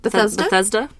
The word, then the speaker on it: Bethesda: So, on March 12th, 2011, Rachel Ortley, the manager of the Lululemon in Bethesda, Maryland, is that how you say it? – Bethesda, Maryland, arrived in the morning to find the door unlocked.